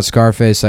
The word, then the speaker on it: Scarface: I was laughing about Scarface. I like to pronounce the name of that movie like you would the word surface. So it's Scarface.